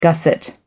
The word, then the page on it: gusset: American English